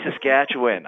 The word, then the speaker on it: Saskatchewan: I know, it's supposed to be Saskatchewan. I keep saying right? Saskatchewan. It's supposed to be win. I know. I'm not. The Saskatchewan listeners are not happy with me right now, so I apologize.